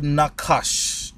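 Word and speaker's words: Nakash (נָחָשׁ): Is the word Nakash. Nakash. Nakash is spelled N-A-C-H-A-S-H. All right, that's Nakash.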